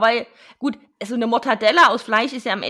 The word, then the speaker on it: Mortadella: Mortadella, so wird es übrigens richtig ausgesprochen, Mortadella, das ist mit einem R und okay.